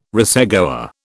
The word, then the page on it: racegoer: British English